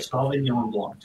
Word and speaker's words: Sauvignon Blanc: Sauvignon Blanc. Just like it's Cabernet Sauvignon, and then it's Sauvignon Blanc.